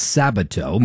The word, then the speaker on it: Sabato: Now, I butchered Larry's name. It's Sabato.